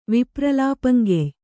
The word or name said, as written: ವಿಪ್ರಲಾಪಂಗೆಯ್